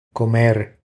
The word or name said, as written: Comer